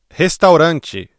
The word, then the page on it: restaurante: ブラジルポルトガル語